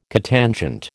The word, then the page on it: cotangent: American English